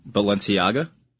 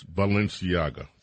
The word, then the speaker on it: Balenciaga: Balenciaga? – Yes, Balenciaga.